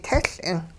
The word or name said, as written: tehL'ing